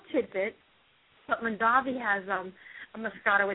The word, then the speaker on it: Moscato: Moscato. Mm-hmm. Moscato.